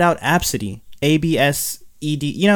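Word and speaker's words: Abcde: Yeah, it's pronounced Absidy. Absidy, yeah.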